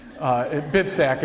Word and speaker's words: BibSac: BibSac is how you say it for people that can't pronounce it.